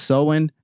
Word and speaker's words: Samhain: So according to Wikipedia, S-A-M-H-A-I-N, pronounced sowing, reads, is a Gaelic festival marking the end of the harvest season and beginning of winter or darker half of the year.